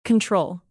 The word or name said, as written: control